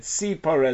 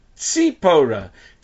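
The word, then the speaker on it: Sipora (סיפורה): I said Sipora as in Samach. Samach Yud Pei Vav Reshe. Sipora Rivka. Yes, it's spelled with a Samach, not a Tzadi.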